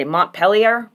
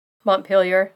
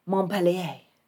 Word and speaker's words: Montpelier: It was a bank in, I want to say this the French way, but I have a feeling they don't pronounce it that way. I'm going to go with what they probably say, Montpelier.